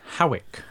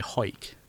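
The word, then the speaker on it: Hawick: But I'm glad you pronounced it, because I was going to say Howick. – Well, you see, that's how everybody pronounces it, but it's actually Hoik. Like, I'm going to hoik my breeks up.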